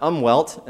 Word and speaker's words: Umwelt: And the term is a German word, spelled U-M-W-E-L-T, and I had been pronouncing that umwelt until I had a German speaker correct me just a couple weeks ago. So it's umwelt.